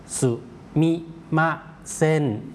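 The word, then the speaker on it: ซู มิ ม า เ ซ น (すみません): คนญี่ปุ่นออกเสียงรวบยาวไปเลยออกเสียงว่าเซนนะครับออกเสียงรวบแล้วลากยาวนะครับเซนครับซูมิมาเซนครับ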